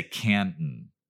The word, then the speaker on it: Canton: It's that, I think it's called like a glottal or something is the technical term, but where you don't pronounce the T. So we say Canton. – Mm-hmm. It's like two N's almost.